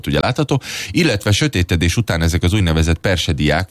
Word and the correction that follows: perseidák: vagy persediák, nem tudom, hogy kell kiejteni pontosan, volt látható. – Magyarul a hullócsillag. – Ja. – Mert a perseidák, perseidák. De maradjunk persediáknál, ez még jobb. – Igen. – Na, de hogy ne legyünk gyagyások, perseidáknak hívják ezeket a meteorokat, hullócsillag magyarul.